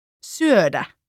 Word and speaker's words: syödä: A Finnish word that uses this sound is, for example, syödä, meaning to eat.